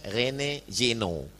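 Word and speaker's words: René Guénon: Ada seorang orientalis masuk Islam, namanya tulisannya Rene Juineon, bacanya Rene Jino.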